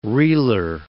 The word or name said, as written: reeler